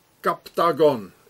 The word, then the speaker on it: Captagon: So here it says, Captagon, it's like Octagon, Captagon, it's the same.